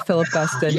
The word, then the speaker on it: Philip Guston: Guston, or whatever. – Philip Guston.